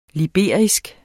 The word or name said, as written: liberisk